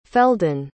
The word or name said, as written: feldon